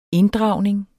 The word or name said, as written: inddragning